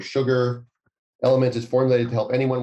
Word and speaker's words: LMNT: That is spelled L-M-N-T, but it's pronounced Element.